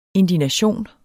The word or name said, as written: indignation